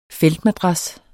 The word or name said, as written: feltmadras